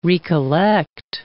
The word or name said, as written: re-collect